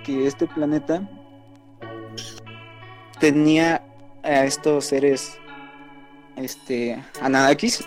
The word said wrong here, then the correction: Anunnakis: Ananakis como se pronuncian, la verdad no, no, recuerdo muy bien. – Anunnakis, ¿no? Ajá.